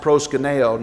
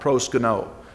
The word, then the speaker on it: proskuneo: The only problem with what Robert read was it's pronounced proskuneo, not proskuno. But that's okay.